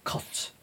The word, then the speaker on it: cotes: C-O-T-E-S, not C-O-A-T-S. They used to make pigeon coats. Or some people pronounce it cots. Pigeon cots, pigeon coats.